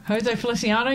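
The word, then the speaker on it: José Feliciano: It's from Jose Feliciano. I said that right. – Jose Feliciano, yep.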